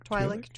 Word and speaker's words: Twi'lek: Yeah, Twi'lek mother and daughter. I believe that's how that's pronounced. Twi'lek? – Twi'lek, Twi'lek, yeah. – I've heard it both ways.